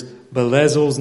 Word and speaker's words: Bezalel: And if Dave Hardy's listening right now, he's laughing about how many times this West Virginian mispronounced that guy's name. Verse 5. But the bronze altar that Bezalel, said it different than before, son of Uri, the son of Hur, had made was in Gibeon in the front of the tabernacle of the Lord.